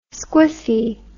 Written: squiffy

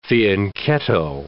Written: fianchetto

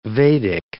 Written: Vedic

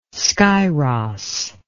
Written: scyros

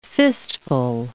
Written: fistful

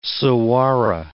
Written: saguaro

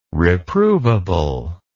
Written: reprovable

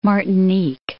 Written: Martinique

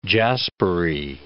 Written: jaspery

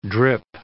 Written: drip

